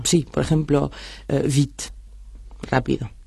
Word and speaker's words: vite: Vit se pronuncia exactamente como si no existiera la E final. – Sin embargo, escribe vite. – Se escribe vite y la E que está por ahí al final hace que se pronuncie la T. – Claro, por eso se dice vit.